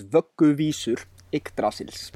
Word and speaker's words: Vögguvísur Yggdrasils: The band's name is pronounced Skalmöld, the song is Niðavellir, and the album is Vögguvísur Yggdrasils.